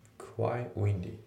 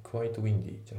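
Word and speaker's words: quite windy: クワイウィンディってね、クワイトウィンディじゃなくてクワイウィンディですね。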